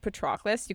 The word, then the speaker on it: Patroclus: So Nestor, who we talked about earlier, goes to Patroclus, and if you think Patroclus' name is pronounced Patroclus, you can just end the podcast here. That's all. I have very, very strong opinions about this. His name is Patroclus.